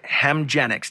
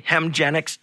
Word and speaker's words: Hemgenix: Canada's just delivered its first publicly funded Hemgenix. Did I say that right? – Yes. – Okay, Hemgenix infusion.